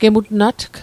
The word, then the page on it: gemutnatg: Mi'gmaq